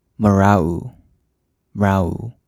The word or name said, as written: Mrauk U